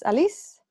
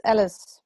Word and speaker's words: Alice: Hi, my name is Alice. It's written as Alice, but my parents thought it was funny to give it a French pronunciation. So I'm Alice.